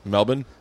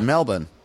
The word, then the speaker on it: Melbourne: Melbourne. That's how they pronounce it, and that's, they like it.